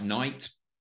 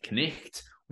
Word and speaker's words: night: With a word like night, presumably, well, previously pronounced something like knicht, we see this idea that phonics and making these connections is something of a best fit.